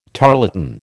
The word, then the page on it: tarlatan: American English